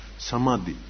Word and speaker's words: самадхи: На санскрите называется самады. Ха, не читается. Самады.